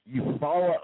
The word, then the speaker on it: Eufaula: Oh, that's, you know, and I've actually heard of that before. I just didn't see it pronounced that way. Eufaula, Oklahoma. That's what it is.